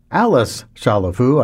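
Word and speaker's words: Alice Chalifoux: Pierre Boulez conducted that 1967 performance by the Cleveland Orchestra, featuring its longtime principal harpist, Alice Chalifou. I assume it would be pronounced that way, since she was from Birmingham, Alabama, not from France.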